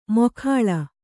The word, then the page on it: ಮೊಖಾಳ: mokhāḷa